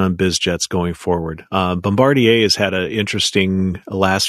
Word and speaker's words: Bombardier: But first, Bombardier or Bombardier. How do you say it? How do you do it? – Bombardier. – Okay, we'll go with that.